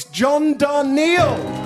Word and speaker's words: Darnielle: From the mountain goats, it rhymes with Tar Heel. Let him know how you feel. It's John Darnielle.